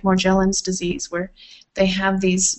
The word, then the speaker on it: Morgellons: Morgellons disease. – Morgellons disease is a byproduct of GM foods.